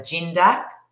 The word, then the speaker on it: Gindac: It's actually Laura Gindac. – Gindac. – As in gin.